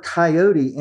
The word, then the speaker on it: coyote: And Mark Twain, by the way, in a book that he wrote in the 1870s, sort of told Americans, this is how you pronounced it. It was called, the animal was called coyote, three syllables.